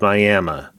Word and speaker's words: Miami: It is spelled Miami, but in Oklahoma, it is Miami.